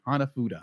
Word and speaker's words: Hanafuda: Okay, Hanafuda. – I know how to pronounce it because that's what Nintendo made when the company was founded in the 1800s.